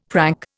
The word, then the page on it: prank: Indian